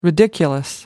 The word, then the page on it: ridiculous: American English